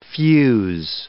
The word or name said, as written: fuze